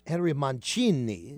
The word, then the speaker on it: Mancini: Okay, now it's interestingly because in Italy his name would really be pronounced Henry Mancini. – That's right, Mancini. – But here in America he's known to most of the American public as Henry Mancini.